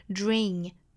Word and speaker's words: during: Isn't who they are during the last conversation, 这个 during, D-U-R-I-N-G, 我们可以读成 during, during, 有一个 de, 耶、 yeah, 的连读同化啊，读成一个 z 的发音，dring dring。当然，这个词也可以读成 dring dring，都是可以的。